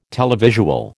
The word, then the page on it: televisual: American English